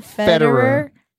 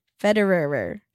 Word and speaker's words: Federer: Federer. – Federer.